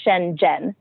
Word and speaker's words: Shenzhen: The ZH in Chinese is like a G sound, so it's Shenzhen.